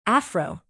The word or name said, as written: afro